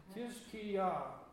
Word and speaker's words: Hezekiah: I did put the Hebrew, or the name, for in English we pronounce it Hezekiah, but it's nothing like that in Hebrew. Let me see if I can pronounce this. I wrote it here in English letters with the Hebrew characters. And basically his name means strengthened of Yah, of God, of the Lord. So that's where his strength comes from. And that was recognized in his name. But as best I can pronounce his name is Kiz-Ki-Yah. Kiz-Ki-Yah. And that's Hezekiah in Hebrew.